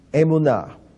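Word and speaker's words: emunah (אמונה): It's pronounced emunah. And emunah, faith, is spelled aleph, mem, vav, nun, he. Emunah.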